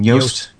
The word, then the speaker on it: Yoast: Yoast.